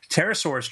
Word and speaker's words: Pterosaurus: He has done, he's done several books, and this is his latest, it's brand new, it's called Pterosaurus Trouble, I like to pronounce the P, Pterosaurus Trouble, and it's a storybook, and it's about, you know, pterosaur.